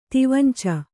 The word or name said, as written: ತಿವಂಚ